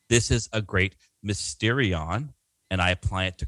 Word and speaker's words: Mysterion: He translates the Greek word Mysterion, or I'm not a Greek scholar, but I've been told the Y is better pronounced as like a U, so it's like, right. Mysterion.